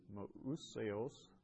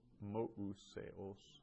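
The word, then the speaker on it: mousseos (Μωϋσέως): Su methetes e tu Jesu, kai auton didaskalon kales, hemes de tu mousseos esmen methetai. So that mousseos, it's a little hard to pronounce, the umlaut over the upsilon indicates that we pronounce the omega and the upsilon separately. So mousseos.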